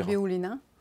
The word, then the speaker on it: Nabiulina: Nabiulina.